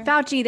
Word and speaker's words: Fauci: Fauci or whatever. – Fauci.